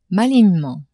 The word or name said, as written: malignement